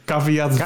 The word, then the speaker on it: caveat: Hoe spreek je het uit? Caviat caveat venditor? Caveat, ja.